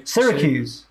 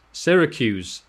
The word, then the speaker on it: Syracuse: Stephen from, I can never get this word right, Syracuse. – Syracuse.